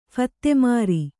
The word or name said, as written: ಫತ್ತೇಮಾರಿ